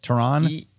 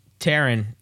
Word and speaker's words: Taron: Taron Egerton. Is that how you say it? Taron? – Taron. Yeah.